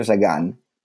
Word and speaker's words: Sagan: The middle name that I proposed was S-A-G-A-N. Some would pronounce what? – It Sagan. Yeah. Sagan as opposed to Sagan. Not Peter Sagan, but Sagan from Carl Sagan, who like was a huge influence on me when I was a little kid.